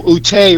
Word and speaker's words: Ute: And as you know, Ute rhymes with Boute.